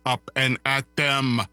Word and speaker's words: up and at them: And he says up and at them, which actually is the proper way to say it.